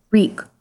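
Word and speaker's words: Reek: Rhaeg, Rhaeg, it rhymes with sneak.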